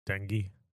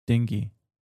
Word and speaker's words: dengue: Army, bred 1 million female mosquitoes that are known to spread yellow fever and how do you say that? – Dengue. – Dengue.